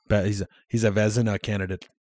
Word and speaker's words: Vezina: And it's pronounced Vezina. – Vezina. – Just Vezina. Vezina. Like there's no I. – So you skip, but there is an I. You just skip it. It's a silent I.